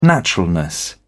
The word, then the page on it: naturalness: British English